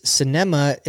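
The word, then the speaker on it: Sinema: Kayleen says, cinema is how it's pronounced.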